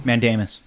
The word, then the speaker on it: mandamus: mandamus. I feel like I said that wrong. – Mandamus. – Yeah, mandamus. Mandamus.